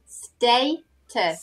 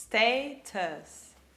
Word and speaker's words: status: Okay, so status. – Status. Your status. – Okay. Yeah, yeah, it's not an A, it's an A. So it's the long A.